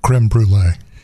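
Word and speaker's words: crème brûlée: Dave, it's technically pronounced creme brulee.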